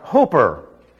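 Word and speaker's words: hooper (ὑπέρ): It is a preposition, unfortunately pronounced hooper. It's hyper with a rr on the end. And if you can't rr, just forget it.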